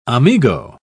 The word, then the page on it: amigo: British English